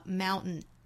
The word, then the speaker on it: mountain: 我们听到原声呢是把它读成了 mountain, 也就是这个 t 的音没有发发成了鼻腔爆破。